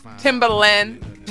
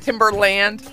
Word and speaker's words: Timbaland: Timbaland, not Timberland, which is always what I want to say.